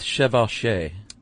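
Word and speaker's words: chevauchée: How do you pronounce this, Chuck? – I'm going to go with chevauché. – Yes, which basically means burn, pillage, loot, and rape.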